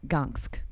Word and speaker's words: Gdansk: Gdansk. – It's a Gonsk. – Gonsk. – Gonsk, yes.